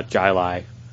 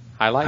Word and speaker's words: Jai Alai: I do really enjoy Jai Alai. It does. – Hi Lai? – Hi Lai. – Oh, it's pronounced with an H.